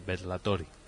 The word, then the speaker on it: Bellatori: No sé si lo digo bien, lo de Bellatori. – Bellatori. Sería como una doble L. Bellatori. Bellatori.